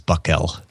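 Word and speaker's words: Buckell: You also said Bucknell. It's Buckel.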